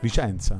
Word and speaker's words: Vicenza: Che tra l'altro Vizenza non si dice. – Vicenza. – È più Vicenza, una roba da Emiliano.